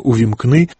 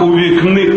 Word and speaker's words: увімкни: Зокрема, вже у цьому році Віктор Янукович не зміг вимовити слово «увімкни». – Цей слоган «увікни», «увікни», «вікнить для себе Україну».